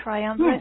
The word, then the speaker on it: triumvirate: And so you mentioned the angels, these three angels, and I don't know how to pronounce this word, but triumvirate, is that it? The angels, yes. – Triumvirate.